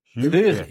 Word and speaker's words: Huyghe: So, huge, oh, for God's sake, Huichi? Huichi? Luigi? No. Huga. – Huga. Huga. I was wrong pronunciation.